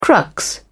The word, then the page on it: crux: British English